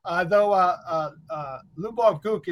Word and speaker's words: Lyubov Guk: Lyubov Gook is a bit of a challenge. – Oh yeah, I know. But yeah, you pronounce it wonderful.